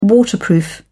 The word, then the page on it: waterproof: British English